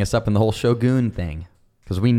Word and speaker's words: Shogun: Also, really quickly, to all the stubborn, dumbass listeners saying that it's not pronounced Shogun, just go on fucking Google and search Shogun pronunciation. It is pronounced Shogun.